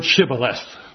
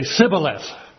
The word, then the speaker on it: Shibboleth: But they can't pronounce the word Shibboleth. They say Sibboleth.